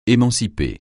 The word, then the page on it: émanciper: français